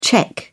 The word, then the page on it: czech: British English